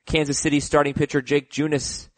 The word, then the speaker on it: Junis: Jake Junis, is it, Heath? Did I pronounce that right? – Yeah.